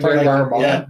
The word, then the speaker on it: Darabont: Like you said with if I'm gonna say his name wrong, Darabont. – Frank Darabont.